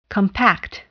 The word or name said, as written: compact